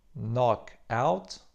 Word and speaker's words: knockout: Oryginalna angielska pisownia to knockout z niemym K na początku, którego oczywiście nie wymawiamy.